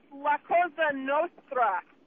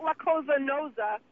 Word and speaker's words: La Cosa Nostra: By the way, it's La Cosa Nostra. Not La Cosa Nosa.